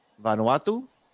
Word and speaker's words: Vanuatu: So here is Lautaro, Vanuatu.